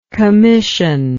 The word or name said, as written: commissively